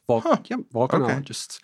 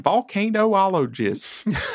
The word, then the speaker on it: volcanologists: And you pronounce it that way, volcanologists? – Yeah, volcanologists. Okay. – I guess it sounds better than volcanoologists.